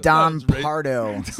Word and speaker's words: Don Pardo: Pronounced Don Pardo.